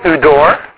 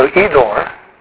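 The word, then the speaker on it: idor (ὕδωρ): Okay, udor, or modern Greek, the utsalan, would be an E sound. So, idor. Not idor, idor.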